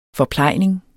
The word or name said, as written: forplejning